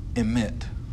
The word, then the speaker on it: emet: The biblical word for faithfulness here is the Hebrew word emit. I guess if we wrote it in English, it would be E-M-E-T. Emit. My pronunciation is probably horrible. But the idea of that is firmness, unbreakableness.